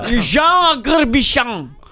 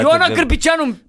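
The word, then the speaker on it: Ion Agârbiceanu: Jean Agarbișan. Jean Agarbișan. – Băi, haide, frate. – Ion Agarbișanu, în gâtul măte de francez. Ion Agarbișanu s-a născut să scrie cum se aude.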